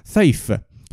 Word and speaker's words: Saiph: Il nome di questa stella è una figata, ovvero Saif. Io pronuncio all'inglese perché è PH, Sai, S-A-I-P-H. Non lo so pronunciare alla eh, all'araba, quindi perdo- mi perdonerete.